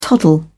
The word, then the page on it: toddle: British English